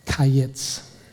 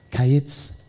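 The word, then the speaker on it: kayitz (קיץ): The word fruit, Hebrew, kayitz. It sounds like the word end, kates. Kayitz, kates. The word fruit, kayitz, the word end, kates.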